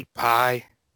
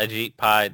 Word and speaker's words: Ajit Pai: Ajit Pai? – Yeah, Ajit Pai.